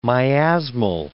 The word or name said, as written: miasmal